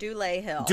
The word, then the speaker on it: Dulé Hill: Dulé Hill.